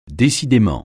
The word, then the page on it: décidément: French